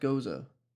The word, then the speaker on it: Gose: I've heard it pronounced goza. G-O-S-E. – It probably is.